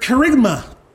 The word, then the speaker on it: kerygma: And it's the word kerygma. Kerygma. It's spelled K-E-R-U-G-M-A.